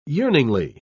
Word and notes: yearningly: British English

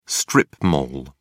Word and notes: strip mall: British English

